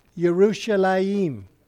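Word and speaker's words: Yerushalayim (ירושלים): Correct pronunciation of Jerusalem is what? Yerushalayim. Okay, there you go.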